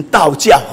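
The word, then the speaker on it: 倒嚼: 利未记十一章，好，我们看一下这个第二节啊，十一章第二节，你们晓谕以色列人说，在地上一切走兽中，可吃的乃是这些，凡蹄分两半，道教的走兽你们都可以吃啊。为什么我读成道教？很多人说奇怪，这个音好像怪怪，什么道教啊？啊，但是你查字典的话，这是破音字啊。道教就是反刍的意思啊，反刍动物这两个字要读成道教啊。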